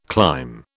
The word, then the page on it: climb: British English